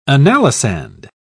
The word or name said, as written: analysand